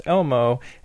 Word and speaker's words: LMO: But I looked up LMO, and Urban Dictionary says that it's pronounced Elmo and is a girl that is extremely hot but has zero ass.